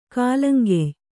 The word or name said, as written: ಕಾಲಂಕೆಯ್